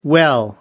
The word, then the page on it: well: American English